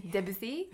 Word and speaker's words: Debussy: We're going to talk a little bit about Debussy. – Mm-hmm. – Did I say that right? – I think so. – Debussy. Debussy, I think, is probably with the French accent.